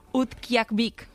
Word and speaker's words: Utqiagvik: Utqiagvik. – Hombre, pues la has dicho genial.